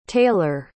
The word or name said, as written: tayler